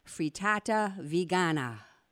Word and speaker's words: frittata vegana: Yes, thank you for your recipe last week of the Swiss rösti And now I'm excited to hear about the Italian meal, frittata vegana. – Yes, you pronounced it very well.